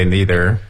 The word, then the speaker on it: neither: Americans usually say neither. – Right. – And、 perhaps British or some very educated Americans will use neither, the more formal pronunciation.